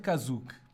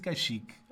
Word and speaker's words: Kashyyyk: mas que neste filme se chama de Kazuk, por alguma razão, mas pronuncia-se Kashyyyk.